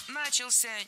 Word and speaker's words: начался: 14:00 начался огромный парад вдоль берег, набережный. – Начался неверно. Правильно, начался. Ударение в этом слове падает на последний слог, поближе к возвратной части «ся».